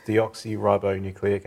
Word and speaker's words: deoxyribonucleic: There's also DNA viruses, which I'm going to try and pronounce this, is deoxyribonucleic acid.